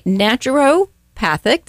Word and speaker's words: naturopathic: She is an ND, a naturopathic. Did I say that correctly? – That is correct.